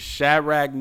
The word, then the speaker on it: Shadrach: I'm a butcher it, but I know how to say it. It's Shadrach, Meshach, and Abednego.